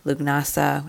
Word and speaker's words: Lughnasadh: And if you want to learn more about it, you can look up Lugnasa, which is spelled Lughnasadh.